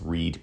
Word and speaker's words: Read: And give Andrew Reed a follow. So again, his last name is spelled R-E-A-D. It's Reed PT on Instagram.